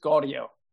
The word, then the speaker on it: Gaudio: It's just Gaudio. So Gaudio. Take the G away.